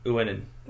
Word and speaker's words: Uinen: How do you say it again? Uinen? – Uinen. – Uinen. – That's how I'd say it.